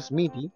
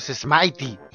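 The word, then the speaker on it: Smithy: Eh, de eh, propiedades Mighty o Smitty no sé cómo lo pronunciarán ustedes, en mi mente era Smitty eh, pero creo que la, correcta forma de pronunciarlo es Smitey en realidad lo, lo, lo, declara eh, eh, propiedad de la pandilla de, Smitey.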